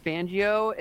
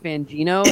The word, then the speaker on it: Fangio: It makes it sound like when he says Fangio, it sounds like Fangino. He's been saying it right the whole time.